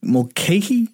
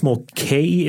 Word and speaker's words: Mulcahy: How do you say his name? Mulcahy? – It's I believe it's Mulcahy. It's M-U-L-C-A-H-Y.